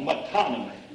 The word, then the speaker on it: metonymy: It's called metonymy. If I can spell it. And that isn't the way you spell it. Metonymy. That isn't the way you spell it, but I pronounced it correctly.